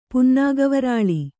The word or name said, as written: ಪುನ್ನಾಗವರಾಲಿ